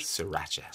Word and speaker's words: Sriracha: Sriracha, is it? – Sriracha.